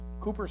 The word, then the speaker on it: Cowper: It's Cooper spelled Cowper, C-O-W-P-E-R, but it's pronounced Cooper, believe me.